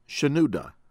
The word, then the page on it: Pope Shenouda: American English